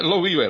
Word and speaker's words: Loliwe: Loliwe, é assim que se pronuncia? – É, Loliwe, exatamente.